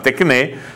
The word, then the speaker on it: Tecné: così come Primo Canale commissiona Tecné,